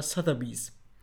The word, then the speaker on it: Sotheby's: Und zwar Sotheby's.